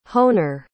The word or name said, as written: honer